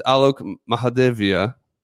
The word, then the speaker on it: Alok Mahadevia: Loki's real name is tell me if you get this right, is Alok Mahadevia.